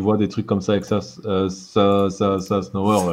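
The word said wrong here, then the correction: Szafnauer: là, je sais jamais le prononcer, je suis désolé. – Saf et Saf Snower.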